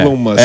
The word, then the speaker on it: Elon Musk: A pronúncia correta é Elon Musk.